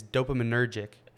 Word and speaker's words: dopaminergic: Like, I think that so much of life is like dopam- dopaminergic. I butchered that. So much of life is dopaminergic, right?